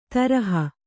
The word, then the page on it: ತರಹ: Kannada